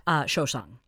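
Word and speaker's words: souchong: Shosang. There, I said it right.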